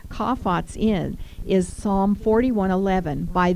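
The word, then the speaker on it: chaphets: And it's C-H-A-P-H-E-T-S, but it's pronounced kaphates.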